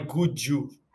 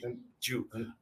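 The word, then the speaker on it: Gücü: Ankara é o nome da cidade e o final é Gudju. O C tem som de J. Gudju.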